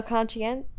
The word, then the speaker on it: conscientious: Wait, how do I say that word? Conscientious. – Say that. You said the fast. Same thing like 4 times. I think you got it. – It sounds like I'm saying it wrong. I'm making a conscientious. – Are you saying I'm making a conscious effort? – No, conscientious. – No, she's right.